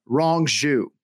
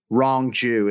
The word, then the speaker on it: Rong Zhu: Speaking of which, we have a fighter making his debut at UFC 261, Rong Zhu. Wrong Jew is how I've been trying to like remember the pronunciation, like incorrect Jew, wrong Jew.